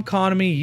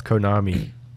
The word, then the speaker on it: Konami: Capcom, Konami, Ubisoft, Take-Two, Warner Brothers, and our Viking overlords over at Koch Media. – It's pronounced Konami. – Whatever. Konami.